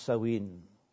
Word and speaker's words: Samhain: It originated some 2,000 years ago when the ancient Druids held a festival, spelt S-A-M-H-A-I-N, but pronounced Samhain.